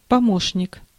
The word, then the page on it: deputy: /ˈdɛ.pjə.ti/